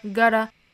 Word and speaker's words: gotta: You guys gotta, 我们刚才讲到 gotta 这个音。那中间的这个 t 的发音其实就是一个 flap t 了。我们用舌尖的部分在硬腭轻轻的一弹而过。 – gotta.